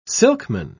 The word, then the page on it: silkman: British English